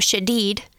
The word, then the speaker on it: Shadid: And after researching, I found Anthony Shaded, except he pronounces his last name Shadid, and mine is Shaded.